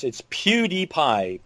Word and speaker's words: PewDiePie: It's PewDiePie.